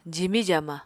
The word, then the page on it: jimi-jaama: apatani